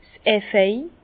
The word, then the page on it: 's Efeu: Bas-Rhin; Herrlisheim